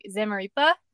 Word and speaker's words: Zamaripa: I'm pretty sure that it's like with an E, like Zamaripa, but people say Ripa all the time, so it's not that big of a deal.